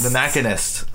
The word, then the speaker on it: Machinist: The Machinist. Is that how you pronounce it? – Yes. – Okay, so I've been saying it wrong this whole time.